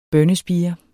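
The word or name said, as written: BØNNESPIRE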